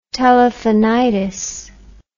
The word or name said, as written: telephonitis